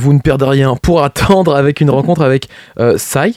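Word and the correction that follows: Cy: j'espère bien le prononcer. Si. – Ouais. – Si, donc C-Y-Si, et Marc Dubuisson.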